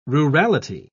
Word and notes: rurality: British English

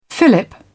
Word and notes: fillip: British English

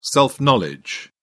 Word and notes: self-knowledge: British English

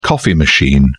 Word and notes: coffee machine: British English

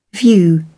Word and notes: view: British English

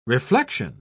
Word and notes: reflexion: British English

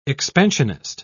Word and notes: expansionist: British English

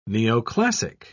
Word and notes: neoclassic: British English

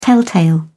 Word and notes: telltale: British English

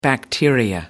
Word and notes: bacteria: American English

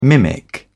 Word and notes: mimic: British English

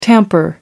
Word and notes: tamper: British English

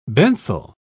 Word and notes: benthal: British English

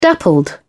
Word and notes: dappled: British English

